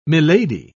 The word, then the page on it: milady: British English